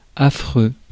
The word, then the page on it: affreux: France; /a.fʁø/